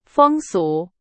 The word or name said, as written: 风俗